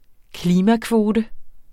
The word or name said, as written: klimakvote